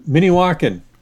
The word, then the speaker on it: Minnewaukan: Minnewaukan? – Minnewaukan.